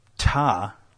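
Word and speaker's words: Ptah: It's compared to the throne of Ta, I think the P is silent, yeah, who was the god before all other gods in Egyptian mythology.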